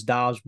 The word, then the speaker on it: Doubs: We do have Doubs confirmed now from a, a tweet, and we really appreciate that. That is how you say Romeo's name. It is Doubs, but we still are in the in the dark about Enigbar, Nagbar.